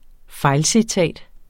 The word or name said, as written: fejlcitat